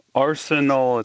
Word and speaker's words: Arsenault: Arsenal, it's a French name. – Arsenal. – So the T is silent, that's right.